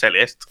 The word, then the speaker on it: Celeste: De hecho, Celeste es un nombre en inglés muy poco usado y es Celeste. – Celeste sí. No es un color. Pero efectivamente, me costó muchísimo captar ese matiz, esa pronunciación acostumbrado al celeste español.